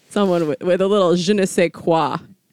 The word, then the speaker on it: je ne sais quoi: Je ne sais quoi.